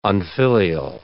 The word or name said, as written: unfilial